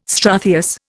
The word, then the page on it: struthious: Indian